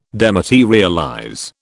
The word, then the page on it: dematerialize: British English